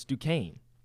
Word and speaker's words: Duquesne: How about Duquesne knocking off BYU 71-66? – Duquesne. – No, it's Duquesne. – Duquesne. – No, it's not. – It's not spelled like that. It's D U.